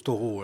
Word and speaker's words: Thoreau: Vous pouvez le prononcer à l'anglaise parce que Thoreau, bon moi je le prononce Thoreau.